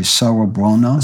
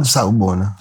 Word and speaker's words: Sawubona: I, later, I learned the Zulu greeting, and I'm not going to pronounce it correctly, Sawubona. Sawubona. Sawubona, mm-hmm. meaning we see you. As noted in chapter one, in South Africa, when you meet somebody, he or she says Sawubona, you reply, Yebo Sawubona, we see you too.